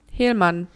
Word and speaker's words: Helman: naja, es ist äh, Hang-Ups von äh, Scott Hellman. Hellman. H-E-L-M-A-N. – Ja, ist doch richtig gesagt.